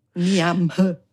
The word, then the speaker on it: Niamh: Oder der Name Niamh, das heißt Licht und das schreibt man N-I-A-M-H. – Oh. – Niamh. Und das schreibt man, sagt man Niamh.